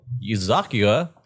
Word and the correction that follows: Yakuza: is that how you say it? – No, meets Animal Crossing. – No, Yakuza.